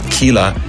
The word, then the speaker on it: Keela: And for this week, I've got an artist called Kela, spelled K-E-E-L-A, and it's pronounced as you would say it in English.